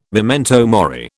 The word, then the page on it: memento mori: British English